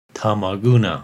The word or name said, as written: Tama Guna